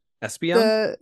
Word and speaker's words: Espeon: Espeon. – Yeah. Espeon. And. – Espeon? – Yes.